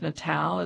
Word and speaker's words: Natale: I think the last name is pronounced Natale. It's spelled N-A-T-A-L-E.